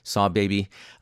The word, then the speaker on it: Sahbabii: Barnacles by Saw Baby, and that's spelled S-A-H-B-A-B-I-I, Saw Baby.